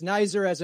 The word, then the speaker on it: Nesser: It's Nesser as in wiser.